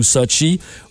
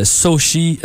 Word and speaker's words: Sochi: Et n'oubliez pas que dans certains cas, surtout quand c'est des trucs américains, là, eh bien, on nous dit Sochi et non Sochi. Fait que peut-être le hashtag S-O-C-H-I et non S-O-T-C-H-I.